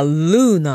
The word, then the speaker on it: lieu: L-O-O じゃないの? – No, this is L-I-E-U. – へー、それでルーって読むんだ。 L-I-E-U で。ルー。 – And it's French. – Ah, France 語なのね。 France 語っぽいスペリングですね、確かにね。 – Yeah. And it means place. – Ah, そうなんだ。 place がルーなんだ。